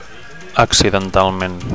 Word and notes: accidentalment: Catalan

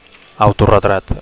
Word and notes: autoretrat: Catalan

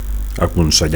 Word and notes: aconsellar: Catalan

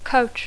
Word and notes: coach: English

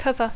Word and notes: cover: English

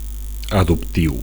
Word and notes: adoptiu: Catalan

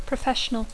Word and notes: professional: English

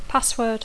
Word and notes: password: English

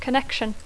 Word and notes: connection: English

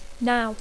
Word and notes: now: English

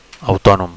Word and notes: autonom: Catalan

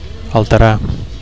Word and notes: alterar: Catalan

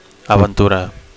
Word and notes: aventura: Catalan

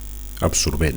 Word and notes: absorbent: Catalan

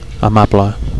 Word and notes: amable: Catalan

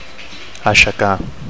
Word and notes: aixecar: Catalan

